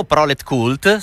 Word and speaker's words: Proletkult: Ecco, quindi insomma siete sempre molto molto attivi, al punto che appunto domani esce in libreria il vostro nuovo romanzo Prolet Cult, spero che la pronuncia sia questa, immagino. – Così, sì, sì.